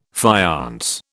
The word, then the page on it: faience: British English